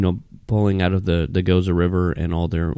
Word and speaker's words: Gose: All right, so Gose is spelled G-O-S-E, by the way. It's not Gose or Gose. It's Gose.